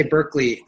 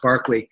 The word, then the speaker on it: Berkeley: I always want to say Berkeley, but it's pronounced Berkeley.